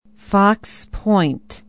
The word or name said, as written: fox point